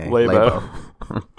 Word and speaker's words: Labo: Labo sounds too much like labia for my taste. – Yeah, so you got to go with the short A or not the long A. – Labo.